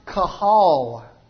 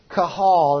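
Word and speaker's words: qahal: It's the first word I have up here on the board, and it's pronounced kahal. It's kahal, and I'm not exactly sure how it comes over. I think it's Q-A-H-A-L. Kahal.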